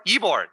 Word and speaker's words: Ybor: Ybor? Is that how you pronounce it? – Ybor? Ybor. There we go. – Ybor. That's it. Yeah.